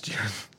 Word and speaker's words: Jiren: Yeah, it's Jiren.